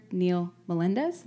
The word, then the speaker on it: Neil Melendez: Or even Dr. Neil Melendez.